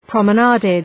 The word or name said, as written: promenaded